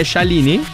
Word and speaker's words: Scialini: Tante volte, ma tante volte sbagliano l'accento. – Ah, ho detto bene, Scialini? – No, Scialini.